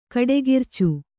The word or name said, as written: ಕಡೆಗಿರ್ಚು